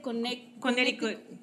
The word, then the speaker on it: Connecticut: Tengo aquí una persona que dice, saludos desde, ah, ¿cómo se dice? – Connecticut. – Connecticut, perdón, no lo pude pronunciar bien, ok.